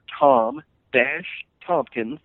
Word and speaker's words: Tompkins: It's my name, Tom-Tompkins. The last name is spelled T-O-M-P-K-I-N-S.